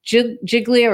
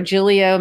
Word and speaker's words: Giglio: Giglio or Giglio material different people pronounce it differently, and Jenks material is impeachment material.